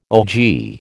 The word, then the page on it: ogee: American English